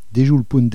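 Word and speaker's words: Dejol Pont: Déjoul Punt de Lyon, ça s'écrit Déjoul D-E-J-O-L Punt P-O-N-T de Lyon L-I-O-N, sous le pont de Lyon. Déjoul Punt de Lyon, ronde du Quercy, et je vous laisse imaginer l'ambiance sous le chapiteau.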